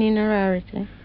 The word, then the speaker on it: Innerarity: Miss Kathleen Inerarity. – That's a rather unusual name. Can I spell it I-N-N-E-R-A-R-I-T-Y? – Yes.